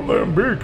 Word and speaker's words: lambic: Okay, I finally said it right. I'm so happy. I'm actually verified by my Belgian friend. I said lambic correctly.